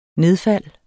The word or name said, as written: nedfald